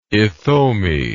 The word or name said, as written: ithome